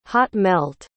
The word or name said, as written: hot-melt